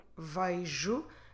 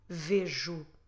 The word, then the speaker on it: vejo: So first of all, on the first person, when we say eu vejo, this is a more standard pronunciation. It is also possible that you get people saying vejo. So you might get vejo, vejo, vejo. So there are several possible pronunciations depending on the accent, depending on if it's a more south accent or from Lisbon or the north. There are all valid possibilities.